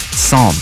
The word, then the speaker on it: Psalm: So now they've got Psalm. Silent P.